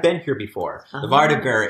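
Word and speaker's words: vardøger: Vardagr. There are many pronunciations, but that's what I found is the common U.S. pronunciation.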